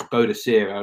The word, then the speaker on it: Boudica: It was Boadicea as I was growing up, and then somewhere after I left high school, it was changed to Boudica, which is the Celtic pronunciation,